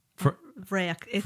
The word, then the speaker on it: bhfraoich: Vrayak. Vrayak. I think it's the flower, rose in the heather. – Spelled B-H-F-R-A-O-I-C-H.